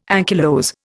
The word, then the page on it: ankylose: Indian